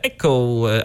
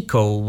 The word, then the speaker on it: Echo: A teraz sobie zagramy piosenkę z echem w tytule, Echo, a właściwie Echo, bo tak to chyba powinno się wymawiać, zgodnie ze sztuką, zaśpiewa nam i zagra formacja Incubus.